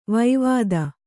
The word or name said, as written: ವೈವಾದ